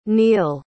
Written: Niel

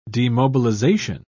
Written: demobilization

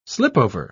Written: slipover

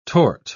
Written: tort

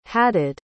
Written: hadad

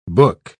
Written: book

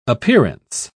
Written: appearance

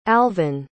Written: alvin

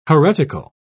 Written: heretical

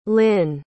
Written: lyn

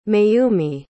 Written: mayumi

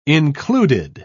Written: included

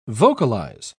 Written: vocalize

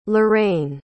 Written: leraine

